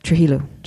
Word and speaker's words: Trujillo: The Trujillo, that's how you pronounce it, regime? – Is that... Trujillo. – Thank you, my Spanish friend.